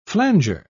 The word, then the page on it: flanger: British English